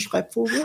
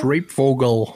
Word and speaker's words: Schreibvogel: Schreibvogel, die können das alle nicht aussprechen, das ist deutsche Schreibvogel. – Schreibvogel.